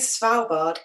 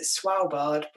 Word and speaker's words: Svalbard: It is Svalbard. I think the people from Svalbard pronounce it Svalbard because the W's and the V's are different the other way around in Scandinavia.